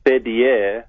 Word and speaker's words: fidye: And the word ransom, fidye, and I'm pronouncing it in the Farsi pronunciation, is pretty powerful there.